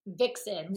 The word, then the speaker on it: Vicsen: Is Vixen the label? – Vixen. And like Vixen being sexy, like okay. V-I-X-E-N. So this is Vic, V-I-C-S-E-N. Vixen.